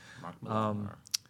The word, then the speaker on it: Mark Millar: Mark Millar.